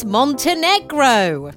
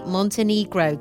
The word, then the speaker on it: Montenegro: please stop saying Montenegro. It's Montenegro. – Oh no, Luke! What have we done? – Well. We've done nothing wrong, Luke, because that is how you say it. If you're British, that's how you say Montenegro. That is correct. You are wrong, Luke. Oh. Montenegro is the British way of saying it. You can say Montenegro. That's quite a common way of saying it, but it's not the native pronunciation, as some may see, as some may think.